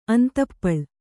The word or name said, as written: ಅಂತಪ್ಪಳ್